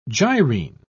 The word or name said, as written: gyrene